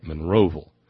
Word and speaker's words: Monroeville: This one out of the little hamlet of Monroeville, and that's how you say it, Monroeville. It's not Monroeville or Monroeville. It's Monroeville. Monroeville, Alabama.